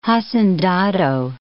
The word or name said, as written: hacendado